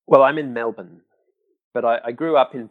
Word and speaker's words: Melbourne: Melbourne, and you say Melbourne. So we gotta. – Yeah, work on that too. Say it like this, Melbourne. – Melbourne. – Melbourne. – Melbourne. – That's it. Okay. Now you got it.